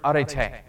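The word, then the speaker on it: Arete (ἀρετή): And so the word that is used is the word arete.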